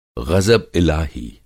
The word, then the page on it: ग़ज़ब-इलाही: غضب الٰہی